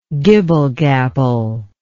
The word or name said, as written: gibble-gabble